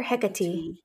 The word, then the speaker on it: Hecate: Daughter of Titan Perses and the nymph Asteria, Hecate, I want to call her Hecate. Is that how you say it? Okay, good.